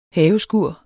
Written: haveskur

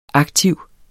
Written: aktiv

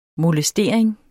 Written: MOLESTERINGERNES

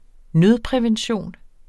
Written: nødprævention

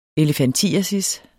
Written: elefantiasis

